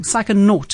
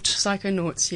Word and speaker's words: Psychonaut: You are known as the Psychonaut podcast queen. I've actually said that wrong. Psychonaut. – Psychonauts, yes.